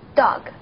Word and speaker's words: dog: 注意 dog 中间呢是短音 o，发音的时候呢要短促 dog。